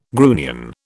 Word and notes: grunion: British English

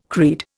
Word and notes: creed: Indian

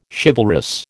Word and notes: chivalrous: American English

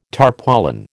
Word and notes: tarpaulin: American English

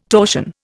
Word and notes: torsion: Indian

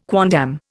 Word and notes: quondam: Indian

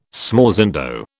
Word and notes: smorzando: British English

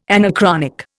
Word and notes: anachronic: Indian

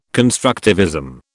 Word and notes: constructivism: British English

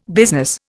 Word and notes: business: Indian

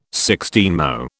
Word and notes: sixteenmo: British English

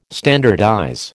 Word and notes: standardize: American English

